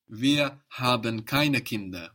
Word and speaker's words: Kinder: But when we put the ER for the plural, actually, this D is not on the end of the syllable anymore. It becomes the first of the next syllable, kinder. So it softens, kinder. It becomes a new syllable, kinder, kinder. – Kinder.